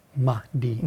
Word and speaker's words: Mahdi: How do you say it? – Mahdi. – Mahdi.